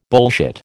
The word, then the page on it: bullshit: American English